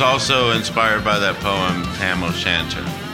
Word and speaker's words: Tam O'Shanter: I pronounced Tam O'Shanter. – You did. You did good.